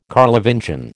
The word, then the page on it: Carlovingian: American English